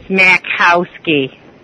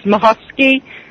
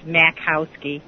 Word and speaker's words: Makowski: It's Makowski. – Oh, so the Makowski. I'm gonna butcher it. It's actually pronounced Mahowski, but in English it's Makowski.